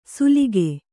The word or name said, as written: ಸುಲಿಕೆ